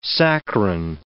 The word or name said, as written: saccharine